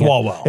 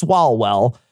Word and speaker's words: Swalwell: Hickenlooper, who's a governor, Sanders, who's a senator, Swalwell or what is it? It starts with isn't it SW? I'm probably mispronouncing Swalwell. – It. It's Swalwell.